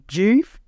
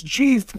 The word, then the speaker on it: Geef: It spelled G-E-E-F, like beef, but G.